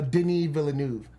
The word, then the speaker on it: Denis Villeneuve: Denis Villeneuve. I thought it was Denis Villeneuve, but it's not him. – Okay. – But that's how it's pronounced.